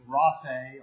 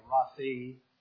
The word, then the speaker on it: Rapha: Yahweh Rapha, or Rafi, depending on who you're reading, how it's pronounced.